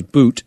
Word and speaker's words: Bout: I called him Bout, but he's Boot.